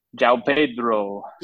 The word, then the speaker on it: João Pedro: João Pedro.